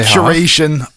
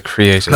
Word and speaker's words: curation: How did you just pronounce that? Say creation. – No, curation. Like, you know, when you curate a playlist. – I thought you said creation.